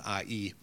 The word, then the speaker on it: Ai: It's pronounced I-E, but spelled A-I, and translated into Hebrew as a heap of ruins.